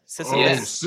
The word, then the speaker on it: Sisyphus: Well, let's bring in Sisyphus. Am I pronouncing that right? Sisyphus. – Yes, you are.